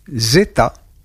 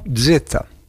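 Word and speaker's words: zêta: Une seule lettre, qui se trouve être la 6e de l'alphabet grec, placée après epsilon et avant eta, et qui se prononce zeta ou zeta.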